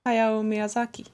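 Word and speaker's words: Hayao Miyazaki (宮崎駿): Hayao Misa. Ja, du Hayao. – säger nog bättre. – Hayao Miyazaki.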